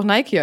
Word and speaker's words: Nike: Genau wie Nike. Das heißt auch Nike eigentlich. Nike.